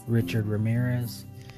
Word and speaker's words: Richard Ramirez: Richard Ramirez.